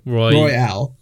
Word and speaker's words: Royale: So Call of Duty has finally made their own Battle Royale. – Like Royal, but Royale.